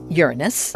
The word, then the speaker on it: Uranus: We astronomers pronounce it Uranus. – It's slightly vulgar.